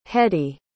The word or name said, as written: hedy